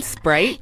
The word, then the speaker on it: Sprite: Does she mean Sprite? – Yes, right.